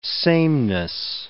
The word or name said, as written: sameness